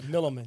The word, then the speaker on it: Milliman: Did I say that right? – That's right, Milliman. Yeah, Milliman.